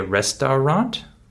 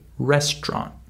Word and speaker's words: restaurant: Just like with the word restaurant. We don't say restaurant, we say restaurant. We skip the middle syllable uh, due to the stress in this word.